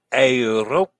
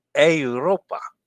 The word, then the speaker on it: Europa: Dennis says, A as in day. Europa. Not I. Europa. Yeah, I know it's A. Europa. Was I saying it wrong? Sorry about that. I knew it was Europa. I know how to pronounce an E. It sounds like an A.